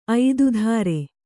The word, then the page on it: ಐದುಧಾರೆ: kannada